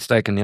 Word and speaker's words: Steichen: Aber ich muss sagen, ich bin wirklich überrascht, dass Steichen, spricht man ihn so aus? – Shane Steichen, ja.